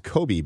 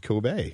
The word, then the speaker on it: Kobe: I heard it on the way in and just like, you know, salivating over the fact that you guys were actually eating what I thought was pronounced Kobe beef. – So did we. Yeah. – But I learned something on the way here. It's pronounced Kobe.